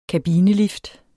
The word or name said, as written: kabinelift